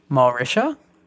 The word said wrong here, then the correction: Mauritius: I believe. It's a country in South somewhere. How did you pronounce it? – Mauritius. – Yeah, that. Yep.